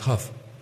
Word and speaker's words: Hough: And if you want to read about how this happened, to read, I always pronounce it Hoag Report, H-O-U-G-H. And then most recently I found out it's pronounced Huff, the Huff Report.